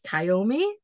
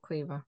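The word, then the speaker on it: Caoimhe: She does have a traditional Irish name that us Brits look at it and go, Kayomi? Because until I was like heard how it was actually pronounced, that's how I would have pronounced it. It's Kaomihe, and you pronounce it Quiva.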